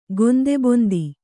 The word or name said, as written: ಗೊಂದೆಬೊಂದಿ